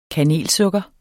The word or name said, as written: kanelsukker